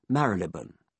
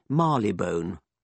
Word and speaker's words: Marylebone: The pre-mutant pronunciation of one particular station is Marylebone. The mutant pronunciation on the Bakerloo line, spoken by a young woman's recorded voice, is Marylebone.